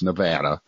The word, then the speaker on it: Nevada: And that's how it's pronounced, Nevada.